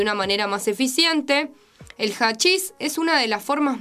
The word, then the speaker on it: hachís: Vamos a la tercera, que es hachis, se escribe con H, pero suena más como una J.